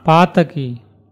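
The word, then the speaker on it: pātakī: If you put the accent, you'll see the. – On the long, on the first syllable. – On the first syllable. – Pataki. – Pataki.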